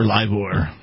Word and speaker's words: livor: So maybe he had the liver mortis before the rigor mortis. – Right, okay. Yes. – Right. – Is it pronounced liver or livor? Because it's spelled livor. And livor, livor.